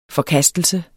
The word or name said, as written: forkastelse